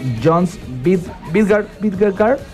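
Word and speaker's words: Jonas Vingegaard: porque a 21 kilómetros del final del danés Jons Vingegaard. – Así es. – ¿Lo dije bien? – Perfecto.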